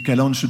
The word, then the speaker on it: calanche: Les calanches de Piana. – Avec l'accent, bravo ! Frédéric, félicitations. Les calanches... Calanque, mais on prononce calanche hein, aussi, et notamment en Corse, les calanches de Piana.